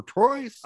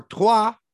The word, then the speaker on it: Troyes: But, yay, EPB came out and played 90 for Troyes. – Oh, nice. – Yeah, or Troyes. As I'm told, it's properly pronounced.